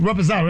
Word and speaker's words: Reposado: Give me a triple Raspado. Triple. – That word just sound expensive. – Yes. – Reposado, yeah. – I said, Reposado. That's how you pronounce it.